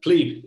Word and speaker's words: plebe: what does this mean to the general Twitter user, the Bitcoin pleb, the taco pleb? Plebe, excuse me, Preston. I'm sorry. I've got to use the correct pronunciation of the word.